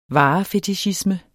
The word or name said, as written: varefetichisme